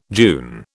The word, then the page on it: June: British English